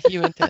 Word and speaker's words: Uinta: Yeah, you into it. I try to remember too, like you into that? You into.